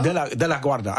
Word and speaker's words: De La Guarda: De La Guarda. Also nicht ganz wie der Flughafen.